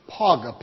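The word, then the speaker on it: paga (פגע): Now, underscore the little word, L-A-I-D. You know what that word is in the original? It's paga. It's pronounced, and it's wrote out in the English, paga, P-A-G-A, so you'll understand what it is.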